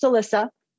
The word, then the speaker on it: Alisa: My name is actually pronounced Alisa.